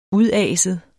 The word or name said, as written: udaset